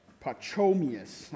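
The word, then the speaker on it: Pachomius: His name was Pacomius. All right, I don't know if I'm saying that. Pacomius. Am I saying that right? No, you know how to say it. Say it out loud. Pacomius.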